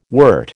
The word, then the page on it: wert: American English